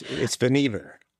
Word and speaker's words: Vannevar: You can tell us how to pronounce it here. – It's Vannevar. – It's Vannevar. – But everybody called him Van. I guess we everybody can't. That made it a lot easier. I was putting the accent on the wrong syllable there.